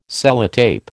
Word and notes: Sellotape: American English

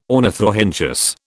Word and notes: ornithorhynchus: British English

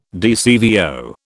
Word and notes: DCVO: British English

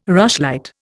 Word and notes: rushlight: Indian

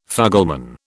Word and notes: fugleman: British English

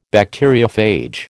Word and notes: Bacteriophage: American English

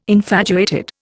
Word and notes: infatuated: Indian

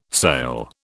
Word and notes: sale: British English

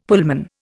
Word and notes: Pullman: Indian

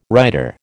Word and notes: rider: American English

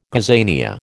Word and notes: gazania: American English

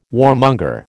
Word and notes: warmonger: American English

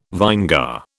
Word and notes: vinegar: British English

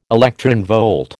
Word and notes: electronvolt: American English

